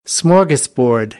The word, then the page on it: smorgasbord: American English; ɔːr